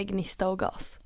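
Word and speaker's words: Gnista + gas: Hur ska man uttala det? – Jag brukar säga gnista och gas.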